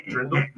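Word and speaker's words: dirndl: It's D-R, it's D-I-R-N-D-L. – Drindle? – But that's the like what the St. Pauli girl wears. – Oh, okay. – It's a drindle.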